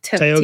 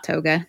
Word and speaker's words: Tioga: Um, she says pretty much any route in Yosemite is nice um, Tioga, I'm not going to say this right. – Tioga. Tioga, Toga, not Toga, Tioga Pass uh, across the top is stunning and heading south is also nice.